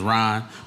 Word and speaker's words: Rahn: My name is Rahn, but my first name is spelled different. It's spelled R-A-H-N. Yes, my mother was tripping. First day of school, my name was always like a question. Ran. Ran. Rahn.